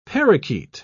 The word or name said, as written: parakeet